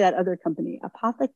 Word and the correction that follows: Anthropic: I think that's the name of it. – Anthropic, that's it. That's how you say it.